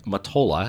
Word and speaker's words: Mottola: This is a film written and directed by Greg Mottola. I think I'm pronouncing his name right. Yeah, I think so.